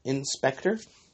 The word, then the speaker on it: In/Spectre: It was an anime, I believe it's pronounced in Spectre. It's like in, and then a slash, and it says Spectre.